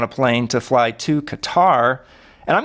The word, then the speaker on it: Qatar: But you just nailed it. The third one that you said was the correct one. Qatar. That's kind of how they say it.